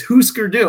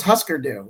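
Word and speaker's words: Hüsker Dü: And it's like, yeah, what do you think those dots mean? Yeah. It's Husker Du. I thought it was Husker Du.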